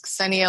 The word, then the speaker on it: Xenia: It was Xenia Lova. Xenia was spelled with an X.